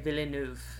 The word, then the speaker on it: Villeneuve: Denis Villeneuve.